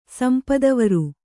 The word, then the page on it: ಸಂಪದವರು: Kannada